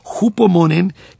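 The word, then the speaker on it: hupomonen (ὑπομονήν): La va a marcar, le va a hacer un círculo allí y en el costadito le va a poner la palabra hupomonen, que es hupomonen. – Constancia. – Se escribe con H, ¿no? – Es una H aspirada, exactamente, como H inglesa, como cuando yo digo Hereford.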